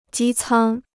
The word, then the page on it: 机舱: jī cāng